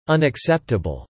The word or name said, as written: unacceptable